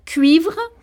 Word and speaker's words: Cuivre: We pronounce it because it's a foreign word. So cuivre, because uh-huh. it's a French word, and river, the English way.